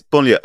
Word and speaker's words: banlieue: God bless you, trying to pronounce that. I was thinking, are you going to go for this word? I have no idea how to pronounce that word. – Yes, it's banlieue.